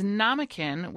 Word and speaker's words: Namakin: It is Namakin, which rhymes with mannequin, Namakin Fur.